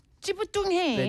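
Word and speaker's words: 찌뿌둥해: 찌부둥해. – 네. 찌부둥. 이게 이렇게 쌍이 뭐라지? 쌍지 and 쌍이 비읍부.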